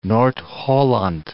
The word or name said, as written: Noord-Holland